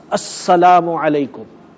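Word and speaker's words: Assalamu alaikum (السلام عليكم): So it is properly pronounced, Assalamu alaikum.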